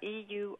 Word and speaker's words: Eurasier: No, Eurasier. Eurasia. – Okay. – Sort of a combo, Europe and Asia together, but Eurasia. So the end is I-E-R. – I-E-R.